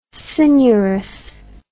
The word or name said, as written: coenurus